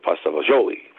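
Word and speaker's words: pasta e fagioli: I think I'm ordering veal and pepper, no bread, on a plate, and a nice bowl of Italian, of course it's Italian, pasta vazzuola. Some people pronounce it pasta vazzuoli, but I think that's what I'm going to order.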